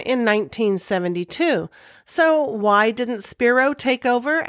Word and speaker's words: Spiro: Later, he turned his attention to a political career, highlighted by a 1968 shoving match with then-Vice President Spiro Agnew. – Bailey leans forward and whispers the correct pronunciation to Les.